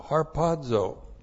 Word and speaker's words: harpazo: H-A-R-P-A-Z, long O, harpazo.